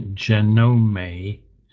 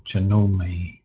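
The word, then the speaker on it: Janome: I was thrown by the fact that he pronounced the make of the sewing machine Genome, and I thought, that can't be right. No, you don't say the final E, do you? And I looked it up.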